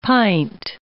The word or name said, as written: pint